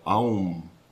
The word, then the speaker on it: Aum: El sonido se inicia en la garganta con la A. A. Luego llena la boca con la U. Au. Y se cierra con la M. Aum.